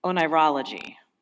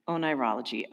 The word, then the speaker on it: oneirology: It's pronounced onirology. Onirology. O-N-E-I-R-O-L-O-G-Y.